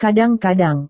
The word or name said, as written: kadang-kadang